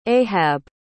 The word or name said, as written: ahab